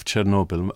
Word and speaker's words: Chernobyl: Well, first of all, the word itself, Chernobyl, am I pronouncing that correctly? – You're pronouncing it correctly. That's the way how the name became known to the world. But the original name of the city or town after which the power plant was called is pronounced Chernobyl in Ukrainian. So it's O instead of E.